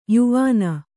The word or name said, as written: ಯವನಿ